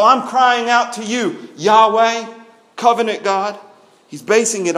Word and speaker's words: Yahweh: This is Yahweh, or as we sang, sometimes it's pronounced Jehovah. But the Hebrew pronunciation, Yahweh.